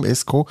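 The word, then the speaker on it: Esco: Ich glaube, ja, spricht man glaube ich so aus. Esko mit C geschrieben, Esko.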